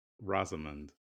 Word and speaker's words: Rosamund: Rosamund is how you Rosamund. That's a ghetto ass name. – It's British. – Who names your child Rosamund? – It's British.